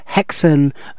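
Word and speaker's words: Häxan: It is a while since I've seen Hexen, that's H-A-X-A-N, but that's got an umlaut over the first A, so hence the pronunciation.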